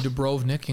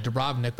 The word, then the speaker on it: Dubrovnik: I learned how you say it. I would have called it Dubrovnik, but now I learned that you have to call it Dubrovnik. And you have to say it with an accent like that.